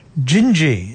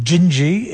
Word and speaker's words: Dindi: Gingy, it's spelt D-I-N-D-I, but pronounced Gingy in Portuguese.